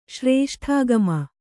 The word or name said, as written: ಶ್ರೇಷ್ಠಾಗಮ